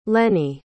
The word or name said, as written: lenny